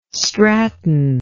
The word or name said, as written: Stratton